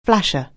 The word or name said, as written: flasher